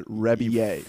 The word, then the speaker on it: Rebillet: Last time I talked about this guy, I pronounced his name Rebillet. But he pronounces that Rebillet.